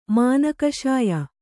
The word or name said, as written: ಮನಃಕಷಾಯ